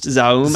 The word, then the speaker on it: ZA/UM: I believe it's just pronounced Zaum, actually. – Zaum or ZA/UM? – I listened to some interviews and it sounded like it was being pretty consistently pronounced Zaum, but it's stylized and written as ZA/UM.